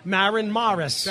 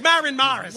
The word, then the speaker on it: Maren Morris: And then today I realized on the drive in that there's a singer, a young female singer, who has both the short A and the short O, Maren Morris.